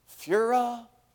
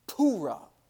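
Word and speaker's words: Pua: I was like, is it Fura? Is it Pahura? And for the windsucker or hacker, as you call it, hoorah, I don't know. I was practicing that, I don't know how well it came out. So I had to look up how to say his name. So the proper name pronunciation, here we go, is Pura.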